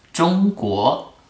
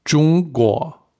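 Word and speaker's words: Zhongguo (中国): Das zweite Wort, so als ein Chinese, der sehr, sehr stolz auf die eigene Kultur ist, habe ich mein Land mitgebracht, Zhongguo. So, China ist Zhongguo. – Zhongguo, sage ich das richtig? – Ja, nach 4 Jahren Lernen, ich finde das so logisch sein, dass du Zhongguo aussprechen kannst.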